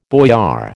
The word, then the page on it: boyar: American English